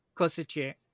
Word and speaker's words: corsetier: I think that I associate the word corset more with a corset Corsetier? Is am I saying that right? – Corsetier yeah